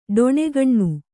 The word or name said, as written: ಡೊಣೆಗಣ್ಣು